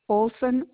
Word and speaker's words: Olson: Loretta, L-O-R-E-T-T-A, Olson, O-L-S-O-N. – All right. I'm sorry, can you spell that last name? – O-L-S-O-N. – So Olson, O-L. – S-O-N, Olson.